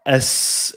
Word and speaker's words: Azores: Azores, I would say it wrong. – Azores.